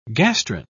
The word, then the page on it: gastrin: British English